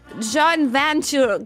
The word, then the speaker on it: joint venture: Si dice in gergo giù marketing, join venture. – Joint venture. Pensa, io che correggo qualcuno in inglese per la pronuncia.